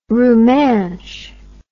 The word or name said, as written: rumansh